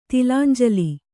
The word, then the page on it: ತಿಲಾಂಜಲಿ: tilānjali